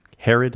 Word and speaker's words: Herod: Herod, did I say that right? – Yeah.